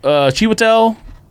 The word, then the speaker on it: Chiwetel: Chiwetel, Ejiofor.